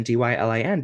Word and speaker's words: Dylan: And my mom reminded me that my name is spelled Dylan, Dylan, because of Bob Dylan.